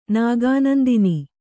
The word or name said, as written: ನಾಗಾನಂದಿನಿ